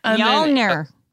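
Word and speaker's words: Mjölnir: Mjolnir. – Mjolnir.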